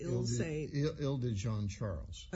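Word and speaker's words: Isle de Jean Charles: But we tend to think of these things in terms of St. Bernard and Lafitte and Ile-Saint-Jean, is that how you pronounce it? – Ile-de-Jean-Charles. – Okay, Ile-de-Saint-Charles.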